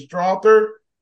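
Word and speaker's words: Strawther: How do you say the kid's name? Is it Strother? – Yeah.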